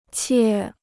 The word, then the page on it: 切: qiè